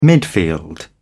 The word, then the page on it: midfield: British English